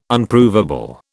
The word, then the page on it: Unprovable: British English